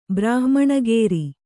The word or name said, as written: ಬ್ರಾಹ್ಮಣಗೇರಿ